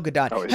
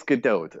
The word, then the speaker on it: Gadot: Have we determined how the proper pronunciation is? – It's Gadot. That's how she says it. – Okay, well then that's what it is. It's Gadot. – It is. Gadot.